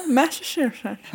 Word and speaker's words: Massachusetts: Wow, har du lärt dig att uttala det? – Ja, Massachusetts.